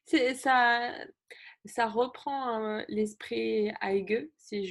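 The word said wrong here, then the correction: hygge: Hugue, ok.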